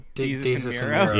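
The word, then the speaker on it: Desus and Mero: I saw he was also on, I think it was DeZeus and Montero. I definitely butchered their name. – Oh, DeZeus and Miro.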